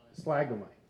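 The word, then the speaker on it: splagchnizomai: It's spladzizomai. Did I say it right? How would you say it? Compassion. Spladzizomai? Splagomai. Yeah.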